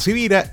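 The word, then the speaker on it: Sivira: Por cierto, mi nombre es Carlos Javier Civira y como estoy claro que mi apellido no es tan común, te cuento cómo se escribe con S de semana y con V de Victoria.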